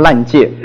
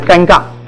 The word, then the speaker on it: 尴尬: 有人不会讲那两个字，一念成烂界，但尴尬。